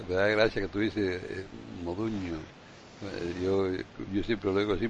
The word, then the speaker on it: Modugno: Tú le llamas Modugno, que es la pronunciación, sí, correcta en español, porque el GNS en italiano suena como la ñ.